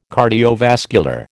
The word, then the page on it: cardiovascular: American English